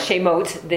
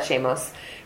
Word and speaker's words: Shemot: Shemot, the name of God. Right. That's what this is. And we call that Shemos. Right, Shemot or Shemos.